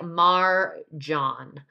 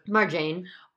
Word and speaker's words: Marjane: Just wanted to correct her name's pronunciation. It's kind of like Marjan.